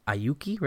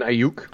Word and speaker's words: Aiyuk: They signed they drafted Aiyuki, right? How do you say it? – Brandon Ayuk.